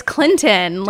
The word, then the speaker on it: Clinton: It's not Clinton. I'm going to Clinton. I'm like, no, it's Clinton. Like, there's a T in there.